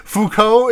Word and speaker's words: Foucault: Um, Foucault is pronounced like fucko, which is ultimately so Reddit that it nullifies any and all arguments laid forth by the man.